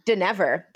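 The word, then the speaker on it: Dynevor: Fourth, of course, is Daphne, played by Phoebe Drivener. I hope I'm saying that right. – Denever. – That was very wrong. I was wrong. Phoebe Denever.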